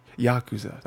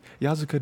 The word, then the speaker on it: Yakuza: Yakuza. That's what it was. I was flipping the two syllables. Yakuza. I was like, yeah, Yazuka does not sound right.